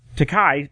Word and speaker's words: Takei: And speaking of stupidity, we'll move along now because here's my favorite stupid person on the internet, and I hope I pronounce his name right. George Takei. Did I say that right? Yeah, George Takei, yep.